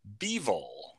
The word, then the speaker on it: Bivol: Canelo Alvarez's hot streak came to an end as he was upset to be a light heavyweight champion, Dimitri Bivol, over the weekend. – Did he say it right? – He did. Finally.